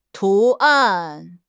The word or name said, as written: 图案